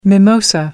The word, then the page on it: mimosa: American English